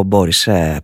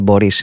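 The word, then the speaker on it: Boris: Σαν σήμερα το 1960 φεύγει από τη ζωή ο Μπόρις Παστερνάκ. Μπορείς, για την ακρίβεια Μπορείς είναι το όνομα, εκεί τονίζεται στα ρωσικά, Ρώσος συγγραφέας, ο οποίος τιμήθηκε με το βραβείο Νόμπελ Λογοτεχνίας το 1958 για το έργο του Dr. Zhivago.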